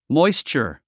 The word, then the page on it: moisture: mɔ́istʃə r; モォイスチャ